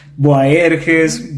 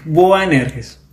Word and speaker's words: Boanerges: Es que es un poquito complicado ya, muchas personas, Boaerges, Boanirges, no, es Boanerges.